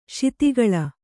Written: ಶಿತಿಗಳ

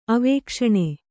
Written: ಅವೇಕ್ಷಣ

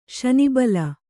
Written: ಶನಿಬಲ

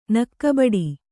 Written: ನಕ್ಕಬಡಿ

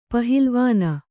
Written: ಪಹಿಲವಾನ

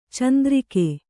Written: ಚಂದ್ರಕೆ

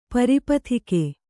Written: ಪರಿಪಥಿಕೆ

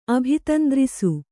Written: ಅಭಿತಂದ್ರಿಸು

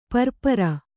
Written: ಪರಿಪೂರ

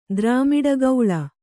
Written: ದ್ರಾಮಿಡಗೌಳ

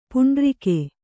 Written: ಪೌಂಡರೀಕ

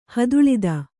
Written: ಹದುಳಿದ

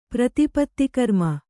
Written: ಪ್ರತಿಪತ್ತಿಕರ್ಮ

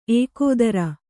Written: ಏಕೋದರ

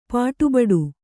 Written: ಪಾಟುಬಡು

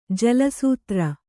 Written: ಜಲಾಸ್ತ್ರ

